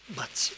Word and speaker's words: bats: B-A-T-S, que se pronuncia diferente bats, y poder, poder, yo puc, tu pots, pots, otra vez una de esas.